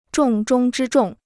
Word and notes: 重中之重: zhòng zhōng zhī zhòng

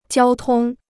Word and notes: 交通: jiāo tōng

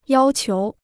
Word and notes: 要求: yāo qiú

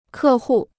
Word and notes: 客户: kè hù

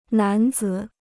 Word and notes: 男子: nán zǐ

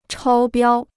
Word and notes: 超标: chāo biāo

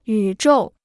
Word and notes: 宇宙: yǔ zhòu

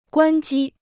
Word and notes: 关机: guān jī

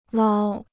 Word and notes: 捞: lāo

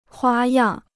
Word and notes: 花样: huā yàng

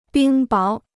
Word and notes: 冰雹: bīng báo

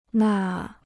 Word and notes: 那: nà

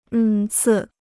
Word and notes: 恩赐: ēn cì

